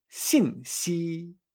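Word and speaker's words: sin si (信息): Y por último, información en chino se dice sin si.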